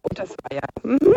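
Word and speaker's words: Ottersweier: Habe ich das richtig ausgesprochen? Otteweier? – Genau, Otteweier.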